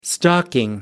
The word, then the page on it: stalking: British English